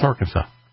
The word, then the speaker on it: Arkansas: Is that Arkansas? Yeah, that's Arkansas.